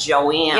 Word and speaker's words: Joan: And people in Indiana pronounce J O A N, not Joan, but Joanne.